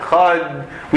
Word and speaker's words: Echad: We elongate the Dalet. We make the Dalet big in the way that we pronounce it. Echad. We try to lengthen the enunciation of the Dalet.